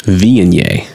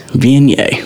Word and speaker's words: Viognier: V-I-E-G-N-I-E? – V-I-G. – V-I-O-G. – Oh. – N-I-E-R. Viognier. – Viognier.